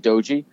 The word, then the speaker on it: Doge: Like people, he calls it doji, but it's spelled D O G E, and okay.